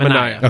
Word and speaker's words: Manaea: Okay, or Manaea. How does he pronounce it? – Manaea.